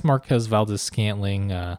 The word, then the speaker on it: Marquez Valdes-Scantling: Still a low end QB1 in what could be a somewhat high scoring game, but I guess Marquez Valdes-Scantling, that's how you say it, by the way, Liz, and Geronimo.